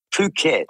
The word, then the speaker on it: Phuket: Well, Fuck it, Kev. I'm pronouncing it wrong. Phuket. – Oh, Phuket, yes.